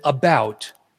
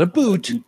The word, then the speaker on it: about: and I pronounce it about, not a boot, about.